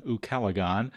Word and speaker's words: ucalegon: My favorite word is ukalagon. – Oh, my gosh. – That's spelled U-C-A-L-E-G-O-N, ukalagon.